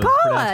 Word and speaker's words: Kala: It's K-A-L-A. So Kala, it's Kala.